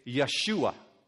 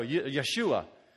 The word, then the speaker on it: Yeshua: If you were going to pronounce it in Hebrew the way you've heard it before, how would you say it? You would say Yeshua.